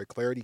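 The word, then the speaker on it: Clardy: I'm Troy Clarity.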